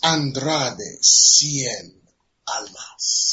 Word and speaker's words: Andrade Cien Almas: It is Andrade Cien Almas.